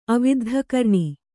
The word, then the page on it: ಅವಿದ್ಧಕರ್ಣ: aviddhakarṇa